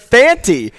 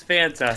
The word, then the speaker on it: Fanta: Well, I heard when that call went down, it sounded like you said Fanti, not Fanta. And I looked at DB. I go, is it Fanti? Have we been saying it wrong? – Sometimes you know that happens. – It's Fanta. – It's like the soda.